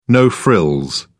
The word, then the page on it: no-frills: British English